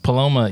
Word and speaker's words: Paloma: That's what it is at Chewy's, a Palermo. – Okay. – Paloma, yeah.